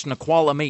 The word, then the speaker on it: Snoqualmie: Like Snoqualmie, I think is missing an entire syllable that is pronounced out loud. – It's Snoqualmie? – It's Snoqualmie.